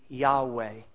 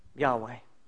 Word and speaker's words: Yahweh: But because it is a to-be verb, we're able to put in the vowels, and the pronunciation of this name would be Yahweh. Yahweh.